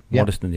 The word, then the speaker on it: Waddesdon: It's sad news indeed of a aircraft and helicopter mid-air crash near Waddesdon. I think that's is that how you pronounce that one? – Yeah, that's it. – Waddesdon, yeah.